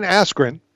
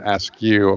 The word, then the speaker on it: Askren: So, Ben Askren has been all over the Twitter world. – Ben Askew, according to Michael Buffer. – Right. – Got his name wrong.